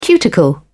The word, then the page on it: cuticle: British English